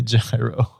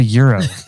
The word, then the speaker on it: gyros: I was like, oh, it's gyro because my English pronunciation would assume it's gyro, right? Yeah. And someone was like, come on, it's a euro.